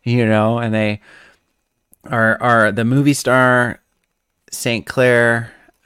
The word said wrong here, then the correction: Sinclair: Am I saying that right? No, Sinclair.